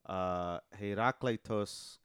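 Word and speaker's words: Herakleitos (Ἡράκλειτος): So the way his name would be pronounced in Greek would be Herakleitos ho Ephesios.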